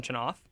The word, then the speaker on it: Chenoff: in the second, the Pronghorns got on the board with Ryan Chenoff. That is how you say that.